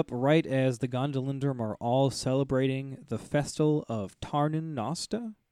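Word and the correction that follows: Tarnin Austa: Did I say that right? Tarnin Osta. – Tarnin Osta. Yeah.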